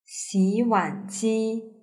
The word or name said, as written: xiwanji